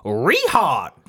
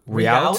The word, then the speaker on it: Rehaut: Well, we pronounce it Rehaut down here in Texas, but it's the Rehaut. – Rehaut. – Yeah, the H is silent.